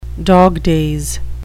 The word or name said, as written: dog days